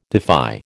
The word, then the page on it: defy: American English